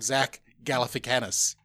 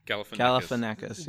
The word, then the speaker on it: Galifianakis: Or my personal pick, because I just love him, but it might be too comedy, Zach Galifianakis. Well, I've said that wrong. Zach Galifianakis. – Galifianakis.